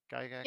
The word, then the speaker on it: Gygax: Gygax? – Yep.